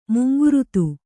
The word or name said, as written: ಮುಂಗುರುತು